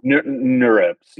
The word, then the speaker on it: NeurIPS: Is it pronounced NeurIPS, like neural? – NeurIPS, yes.